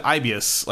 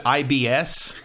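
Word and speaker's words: Aibius: Also, the name of the company is Ibius, and everyone, and you're supposed to pronounce it Ibius like that, but some of the voice actors pronounce it IBS.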